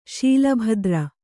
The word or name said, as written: ಶೀಲಭದ್ರ